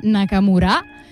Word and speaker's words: Nakamura: Allora, adesso passiamo a Aya Nakamura, con l'accento sulla A.